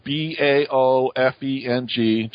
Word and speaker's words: Baofeng: It's called a Bale, fang, or fang. Uh, a lot of people pronounce it differently. It's B A O F E N G.